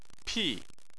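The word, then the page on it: pi4: tone 4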